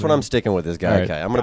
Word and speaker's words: Gaikai: No, I think that's how it's pronounced, Gaikai.